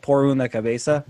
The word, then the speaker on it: Por una cabeza: called "Por una cabeza."